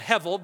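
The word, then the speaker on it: Hevel (הבל): The vowel markings on this indicate that's a soft B, soft beit. That's why you get Hevel or Hebel. So I, am going to call it Hevel because that's really, really, I think, more accurate how to pronounce it.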